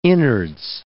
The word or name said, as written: innards